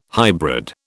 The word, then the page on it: hybrid: British English